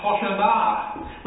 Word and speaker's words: Hoshanah: The first was a Hebrew phrase, Hosanna. Or, not that I'm very good at Hebrew, but it was probably pronounced Hoshanah, which means save us.